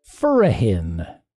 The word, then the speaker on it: Furrahin: And the word for this crazy round is Furahin. Furahin. That's spelled F-U-R-R-A-H-I-N. Furahin.